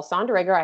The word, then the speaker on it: Sonderegger: His name's Paul Sonderegger.